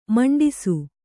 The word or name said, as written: ಮುಂಡಿಸು